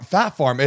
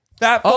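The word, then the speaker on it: Phat Farm: Fat Farm. It's Fat Farm. – It's Fat Farm? – P-H-A-T. Fat Farm.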